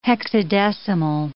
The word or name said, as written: hexadecimal